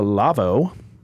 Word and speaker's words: Lawo: This word is an L-A-W-O-S. Laws? – So Laos. – If I delete the L, that's the name of the brand, L-A-W-O. How do you pronounce that? – Lawo. – No, incorrect. – Lawo. – It's a Lavo. – Yeah, screw that. That's not true. – See, the manufacturer is a German, and so that's how you pronounce it, I guess.